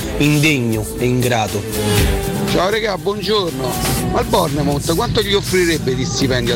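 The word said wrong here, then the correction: Bournemouth: Si pronuncia Bonmus. Aia. Bonmus. Mus, mus, mus.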